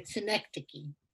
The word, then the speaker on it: Synecdoche: He can be seen in films Marcus Story, The Diner, and Cynic Duck. I think that's, I'm saying that correctly. – Synecdoche. – Sorry, Synecdoche, boy.